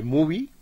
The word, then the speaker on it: MUV: en la aplicación de Movie, así se escribe M-U-V, grande y latina, está la película.